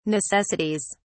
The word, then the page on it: necessities: British English